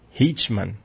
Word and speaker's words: Eichmann: Ella comentaba que no fue propiamente él quien cometió los crímenes, porque recuerden que Eichmann, eso es, así está bien pronunciado, es quien llevaba todos estos trámites administrativos comercializando con personas.